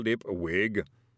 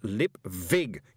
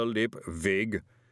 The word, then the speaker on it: Lipwig: "Up we get, Mr. Lipwig." – "It's pronounced Lipvig, you moron," he moaned. "A V, not a W." – "Up we get, Mr. Lipvig," said the booming voice as his broom crutch was pushed under his arm.